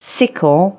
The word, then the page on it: sickle: English